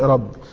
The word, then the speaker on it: ربي: هي مكتوبة بالحروف اليونانية بتتنطق ربي.